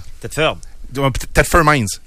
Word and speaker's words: Thetford Mines: C'est comme Thetford Mines. – Thetford Mines. Il n'y a pas de D quand tu viens. – On prononce pas le D.